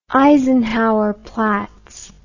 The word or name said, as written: eisenhowerplatz